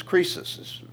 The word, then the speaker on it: Croesus: Well, the story goes that it was Croesus, his name. It's actually not spelled like that, but that's the way it's pronounced.